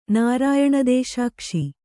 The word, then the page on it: ನಾರಾಯಣದೇಶಾಕ್ಷಿ: nārāyaṇa dēśākśi